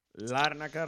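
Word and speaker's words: Lárnaca: Larnaca, recordad, hay que pronunciarlo bien, que en España yo creo que lo pronunciamos todos mal, no poníamos la tilde ahí en la primera A.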